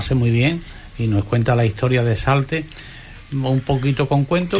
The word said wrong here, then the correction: Saltés: Tiene acento en la E, ¿eh? Saltés. – Salté, eso es, Salté. Lleva razón, pero es Salté.